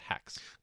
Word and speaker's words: H4X: We're going to speak to Cole Gurman of it's written H4X, but it's pronounced Hex. – Correct.